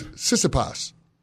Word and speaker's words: Tsitsipas: How do you say his last name again? – Tsitsipas? – No, it's Tsitsipas. Tsitsipas. The T's are silent.